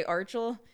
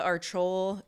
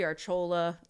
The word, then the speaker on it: Archual: I tell people that I don't have, like, a stage name, but I have a stage pronunciation. – I love that. – Because I usually say Archul. – Yeah. – But I just, like, Samantha Archul. It used to be Archola.